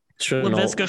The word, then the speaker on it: Laviska: I can't pronounce it. Lavishka. – Laviska Shenault. Yeah, he looked great.